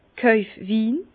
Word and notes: Kojfwin: Bas Rhin; Herrlisheim

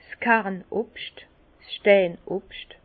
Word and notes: 's Kern Obscht, Stein Obscht: Bas Rhin; Herrlisheim

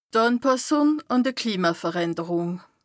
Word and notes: d' Ànpàssùng àn d' Klimaveränderùng: Bas-Rhin; Strasbourg

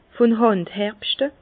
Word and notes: vun Hànd herbschte: Bas-Rhin; Herrlisheim

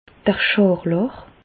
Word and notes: d' Schàrlàch: Bas Rhin; Reichshoffen

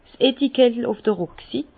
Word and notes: 's Etikettel uf d'r Rucksit: Bas-Rhin; Herrlisheim

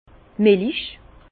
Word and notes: d' Milich: Alsatian; Bas-Rhin; Herrlisheim

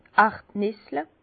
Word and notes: d' Erdnissle: Bas Rhin; Herrlisheim

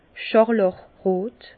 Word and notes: schàrlàchrot: Bas-Rhin; Herrlisheim

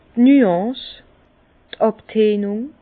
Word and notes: d' Nuance, d' Àbtönung: Bas-Rhin; Herrlisheim

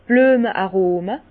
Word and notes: d' Blüeme-Arome: Bas Rhin; Herrlisheim

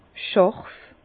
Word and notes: schàrf: Bas-Rhin; Herrlisheim

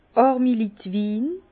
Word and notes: Àrmi-Litt-Win: Bas-Rhin; Herrlisheim

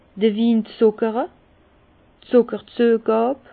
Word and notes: d'r Win zuckere: Bas-Rhin; Herrlisheim

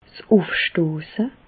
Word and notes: 's Ùfstosse: Bas Rhin; Reichshoffen

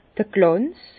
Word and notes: d'r Glànz: Bas Rhin; Herrlisheim

